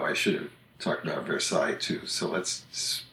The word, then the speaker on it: Versailles: But there are cities by that name in Illinois, Kentucky, Ohio, and Pennsylvania, and they're all pronounced Versailles. – Mm-hmm. Which is how you, once again, how you want to pronounce it when you read those letters. If you didn't know it was a French word, that's how you might pronounce it.